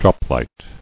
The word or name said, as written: Droplight